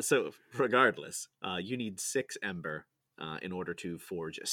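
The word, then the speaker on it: Æmber: I pronounce it Ember. It's spelled AEMBER, and some people pronounce it Amber, some people pronounce it Ember. It's the same thing. – I'm in house Amber. – I'm definitely Amber. – I mean, if you think about how ether or aether is pronounced, it could be Amber.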